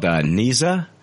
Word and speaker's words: Nezha: It's called Neza, and that's spelled N-E-Z-H-A.